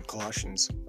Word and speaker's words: Colossians: And it's also those Bible verses in Colossians.